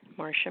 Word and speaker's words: Marcia: My name is spelled M-A-R-C-I-A. My last name is M-I-G-E-T, Marcia Maget.